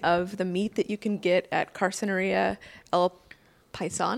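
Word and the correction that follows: carnicería: Did I say that all wrong? Did I butcher that? – Yes. – Can you say it? – Well, I think I believe it's Carniceria. – Carniceria. There we go.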